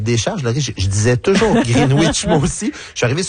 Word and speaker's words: Greenwich: Et là, on m'a signalé que on ne doit pas prononcer green comme green, mais plutôt green. Et on ne prononce pas le W, donc ça fait un Greenwich.